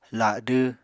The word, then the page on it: ladw: Apatani